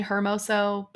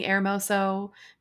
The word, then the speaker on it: Hermoso: Americans say Jenny Hermoso, but it's Jenny Hermoso because you don't say H's in Spanish.